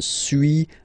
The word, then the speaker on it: suis: Okay, so first one, suis, as you've been noticing, you don't pronounce the final S.